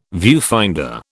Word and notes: viewfinder: British English

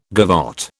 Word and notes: gavotte: British English

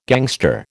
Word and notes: gangster: American English

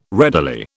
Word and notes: readily: British English

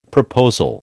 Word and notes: proposal: American English

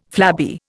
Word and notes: flabby: Indian

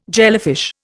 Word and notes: jellyfish: Indian

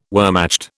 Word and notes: Wehrmacht: British English

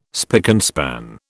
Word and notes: spick and span: British English

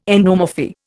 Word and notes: endomorphy: Indian

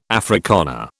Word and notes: Afrikaner: British English

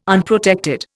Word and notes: Unprotected: Indian

